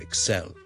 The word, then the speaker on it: excel: 我哋叫做 to excel at something。个重音呢其实呢佢系应该放喺 the second syllable 嘅。 So, excel.